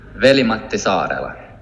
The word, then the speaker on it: Veli-Matti Saarela: Veli-Matti Saarela.